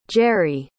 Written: jerrie

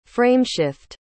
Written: frameshift

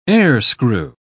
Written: airscrew

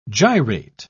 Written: gyrate

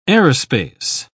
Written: aerospace

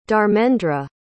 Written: dharmendra

